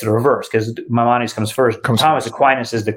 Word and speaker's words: Maimonides: is it Maimonides? Am I pronouncing that right? – Maimonides, yep.